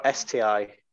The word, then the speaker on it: STI: Yeah, it came out of this sty. or STI. Sorry, I said it wrong.